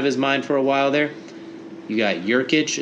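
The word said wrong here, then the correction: Nurkić: Yusuf Nurkic. Yusuf Nurkic. It's the JN, Jokic, not the NJ.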